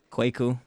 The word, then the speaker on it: Kweku: Kwaku.